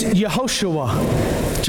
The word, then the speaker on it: Yehoshua: Jesus is the Greek pronunciation of his name, Jesus, right? Jesus. His Hebrew name is Yehoshua, Joshua.